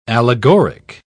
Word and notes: allegoric: British English

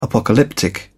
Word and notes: apocalyptic: British English

